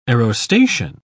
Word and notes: aerostation: British English

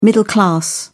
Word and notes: middle-class: British English

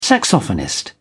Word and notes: saxophonist: British English

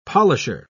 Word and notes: polisher: British English

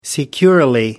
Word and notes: securely: American English

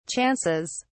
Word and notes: chances: British English

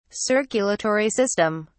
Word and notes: circulatory system: British English

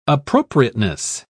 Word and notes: appropriateness: British English

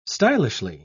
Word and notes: stylishly: British English

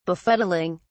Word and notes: befuddling: British English

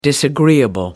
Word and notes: disagreeable: American English

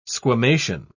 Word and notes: squamation: British English

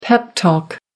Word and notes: pep talk: American English

